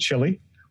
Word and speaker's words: Chili: It's C-H-I-L-I. Rest of the world pronounces it Chili. We pronounce it Chili.